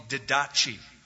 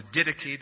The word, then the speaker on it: Didache: It's called the Didache or the Didache, depending on how you want to pronounce the Greek.